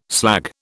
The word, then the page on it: slag: British English